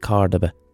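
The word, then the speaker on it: Córdoba: I have to call it Cordoba now because I used to call it Cordoba, and I've been corrected so many fucking times that I'm now going to start calling it Cordoba, the correct pronunciation.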